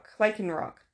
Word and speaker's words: Lycanroc: It's Lycron? Lycron? Lycanroc? Lycanroc. That makes sense. Am I pronouncing that right? – Yeah.